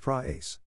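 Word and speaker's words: pra-ace (πραεῖς): The original Greek word is pi rho alpha epsilon sigma, pronounced pra-ace.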